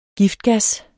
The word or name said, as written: giftgas